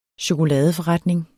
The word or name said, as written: chokoladeforretning